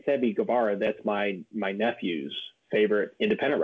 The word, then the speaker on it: Guevara: Sammy Guevara, I think it's pronounced. – Guevara? Yeah.